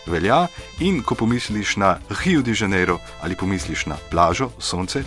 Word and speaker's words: Rio de Janeiro: A lahko zavrtiš nazaj, pa počasi Rio de Janeiro izgovarjava. To je brazilski R, še posebej, če je na začetku besede je bolj podoben črki H, v Rio de Janeiro, poje Gilberto Žil.